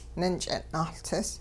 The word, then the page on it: ninch'ing' nahLtsis: Hupa